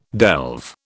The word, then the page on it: delve: British English